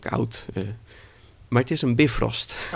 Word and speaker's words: Bifrost: En nou ja, het wordt ook in Marvel wordt het zo uitgesproken, Bifrost.